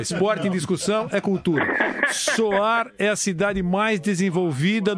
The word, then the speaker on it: Sohar: Oman é um país Como é que se escreve Sohar? – Sohar é S-O-H-A-R. – Soar, né? Soar. – Isso.